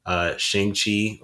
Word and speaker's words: Shang-Chi: Shang-Chi, I think it's actually pronounced, drops in September.